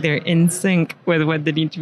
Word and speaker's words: Insynq: So could be in sync. – Sounds right.